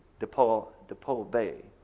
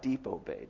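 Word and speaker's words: Depoe Bay: It's not Depot. The locals say it's DePaul Bay, something like that.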